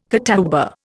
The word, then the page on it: catawba: Indian